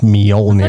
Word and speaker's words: Mjolnir: Almost like meow, but it's Mjolnir. – I thought it was like Mjolnir. – Okay, if that makes you feel good, say Mjolnir.